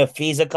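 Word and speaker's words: Napheesa: But Breanna Stewart and Napshia Collier are founding a United States off-season women's basketball league. – You mean a FISA Collier? – Thank you. I knew you'd ha know how to say it.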